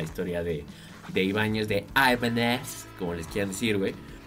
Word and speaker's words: Ibanez: Ha llegado la hora de hablar del flaco Ibáñez, ¿no? ¿Vamos a hablar? – ¿O de qué me habías dicho que íbamos a hablar? Pero, güey, no se dice Ibáñez, se dice Ibanez, güey.